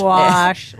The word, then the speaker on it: gouache: Gouache. Like a squash. But with a G.